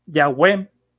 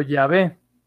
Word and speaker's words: Yahweh: Eso significa, y esta palabra los eruditos eh, piensan que se pronunciaba Yahweh o Yahvé.